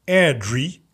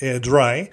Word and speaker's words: Airdrie: Maar volgens mij spreek je het uit als Airdrie, anders is het Airdry.